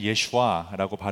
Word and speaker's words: 예슈아: 예수와 어, 더 정확하게는 예슈아라고 발음하는 이것은 예수님의 그 아람어 본 이름 발음, 발음입니다.